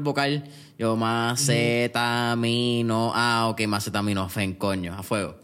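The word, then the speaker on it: acetaminofén: ¿Es macetaminofen o macetaminofen? – con acento en la de macetaminofen. Fen, ok, exacto, por eso.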